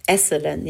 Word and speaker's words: Esalen: Esselen, ja. – Sehr Esselen.